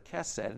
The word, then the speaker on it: hesed: There's a Hebrew word, it's pronounced either hesed or kesed, and it means faithful love, loyal love, kindness.